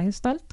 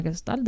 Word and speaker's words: Gestalt: la Gestalt, o la Gestalt, depende de cómo lo queramos pronunciar.